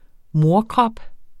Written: morkrop

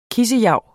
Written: kissejav

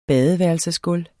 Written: badeværelsesgulv

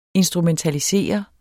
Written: instrumentalisere

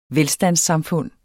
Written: velstandssamfund

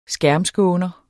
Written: skærmskåner

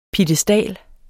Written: PIEDESTALERNE

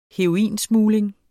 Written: heroinsmugling

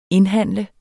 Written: indhandle

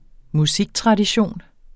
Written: musiktradition

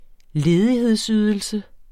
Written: ledighedsydelse

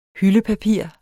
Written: HYLDEPAPIR

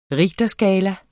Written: richterskala

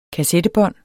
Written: kassettebånd

